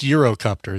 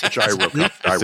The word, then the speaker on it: gyrocopter: You booby trap your gas, you booby trap your gyrocopter with a snake, or is it pronounced Eurocopter? Is it a Eurocopter? – It's gyrocopter.